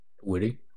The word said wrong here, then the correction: Whitey: Yeah, it's actually pronounced Whitey, but yeah, Whitey, like, there's nothing to say.